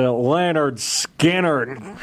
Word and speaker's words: Lynyrd Skynyrd: They pronounce it Lynyrd Skynyrd.